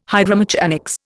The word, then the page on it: hydromechanics: Indian